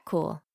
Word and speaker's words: Kuhl: I'm Kat Cool, and I can be found on Twitter at Wolves Are Cool, with cool spelled like my last name, K-U-H-L, or also on Twitter at Campaign Pod for the show.